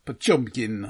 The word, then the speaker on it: Potemkin: We are discussing a purported masterpiece and badly pronounced by me, at least, Battleship Potemkin. – Or Potemkin, as would have been pronounced at the time, more or less. – That sounds much nicer, actually.